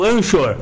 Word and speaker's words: Anchor: Anchor. Se escreve com CH.